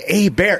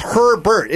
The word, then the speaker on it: Herbert: I was just like, it's not Abair. It's Herbert. It's spelled H-E-R-B-E-R-T. Herbert.